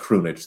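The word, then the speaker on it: Krunić: Her last opponent was the Chronic. – It's true. It's true. I think it's pronounced Krunic, though, but I like that.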